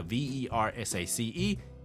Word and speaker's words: Versace: When you see the V E R S A C E, you pronounce it Versace.